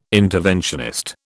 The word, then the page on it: interventionist: British English